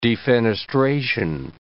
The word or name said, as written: defenestration